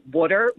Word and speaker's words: water: people who don't pronounce the word W-A-T-E-R like we do, water, we pronounce it water.